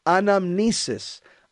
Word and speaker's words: anamnesis: It's pronounced anamnesis.